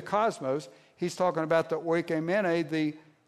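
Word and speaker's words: oikomene (οἰκουμένη): Now, a second word that is used is less familiar, and it's pronounced oikomene.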